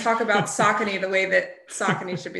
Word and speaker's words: Saucony: First of all, can we just briefly pause and just mention that it's Saucony? – This Saucony.